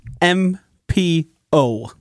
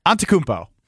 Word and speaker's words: Antetokounmpo: Antetokounmpo. – Josh said a lot more letters than you did. – Yeah, I was a little short. Antetokounmpo. – Antetokounmpo. – I forgot a syllable. – Uh-huh. – Antetokounmpo.